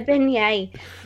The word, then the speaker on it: beignet: I struggle to pronounce this word, so I know that you'll probably be able to help me out with it. A beignet? – Chocolate. A beignet voodoo donut, which looks delightful.